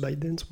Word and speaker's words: ByteDance: Non, c'est ByteDance. ByteDance. – ByteDance.